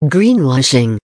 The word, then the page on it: greenwashing: American English